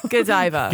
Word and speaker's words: Godiva: Godiva. – Godiva. Damn it. – Oh my God. You. – It's spelled like Go Diva. Like you go, go Diva. Diva. Go Diva.